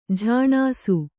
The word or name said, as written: ಝಾಣಾಸು